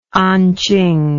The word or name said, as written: anqing